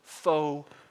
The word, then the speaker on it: faux: Now the word there, spelled F-A-U-X, is pronounced faux, and it means imitation, artificial, not genuine, fake, false.